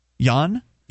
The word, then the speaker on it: yuan: The 30-year-old man, who was not identified, was said to have spent more than 10,000 how do you pronounce this? – Yuan. – Okay. It's 10,000 yuan, not yen.